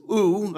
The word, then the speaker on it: ou: The other Greek word, which is ou, I don't know if I pronounced that right. If Anna, our resident Greek scholar, was in church this morning, she would either nod or shake her head to tell me I was on the right track. But I'm pretty confident that's how you pronounce it. The other word for no in Greek is ou.